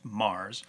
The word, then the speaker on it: Magrs: And so here we're going to talk about two audios by the writer Paul Mars, M-A-G-R-S, but pronounced Mars.